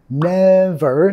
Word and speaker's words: never: You never, enfatizando la primera sílaba ahí, you never know.